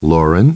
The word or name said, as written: Lauren